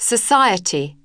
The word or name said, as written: society